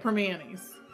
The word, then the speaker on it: Primanti's: And that's how you say it, Permanis. – Permanis. – We don't enunciate a lot here in Pittsburgh. There's a lot of words that don't get the full enunciation, as the spelling goes.